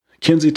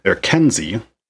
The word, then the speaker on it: Kinsey: Sam calls her Kenzie, which is an odd way to pronounce it, and it was enough for me to put in my notes that he calls her Kenzie instead of Kinsey.